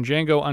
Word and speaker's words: Django: He was Amerigo Vespucci in Django Unchained, as a reference to his appearance in the lead of the original Django film. – D-J-A-N-G-O. The D is silent.